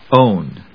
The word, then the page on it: own: óʊn; ˈəʊn